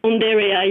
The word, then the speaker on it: Undaria: Well, tell us about the, am I pronouncing it right, Undaria, and how you came across it? – Undaria, yes.